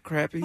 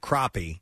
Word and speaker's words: crappie: Crappy. That's how you pronounce it in the South. Yep. – What do y'all call it? – When I lived in the Midwest, it was called crappie. But I lived in South Carolina.